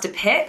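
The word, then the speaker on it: De Pijp: I've actually stayed at the sister hotel, which is the Sir Albert, which is in an area called, I think it's pronounced De Pip, but it's spelled P-I-J-P.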